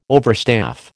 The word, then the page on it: overstaff: American English